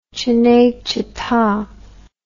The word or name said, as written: cinecitta